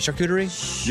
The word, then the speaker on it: charcuterie: Charcuterie? – Yeah, charcuterie.